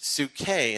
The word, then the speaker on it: psuche: It's pronounced psuche in the Greek, but it's your psyche.